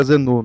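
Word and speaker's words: Xenu: chamado Zenu, acho que se pronuncia Zenu.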